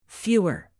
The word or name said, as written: fewer